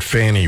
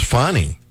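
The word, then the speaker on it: Fani: Fulton County, Georgia, District Attorney Fonnie Willis, and I'm willing to bet dollars to donuts that they meant to pronounce that Fanny Willis, but misspelled it. – I always thought it was. – No, it's Fonnie. That's how they're pronouncing it, Fonnie.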